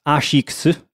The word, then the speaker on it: ASICS: So in Japanese, it's pronounced ASICS.